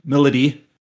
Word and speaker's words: Milady: Milady, it looks like this, the name looks like My Lady, but it's pronounced Milady, and Saizo.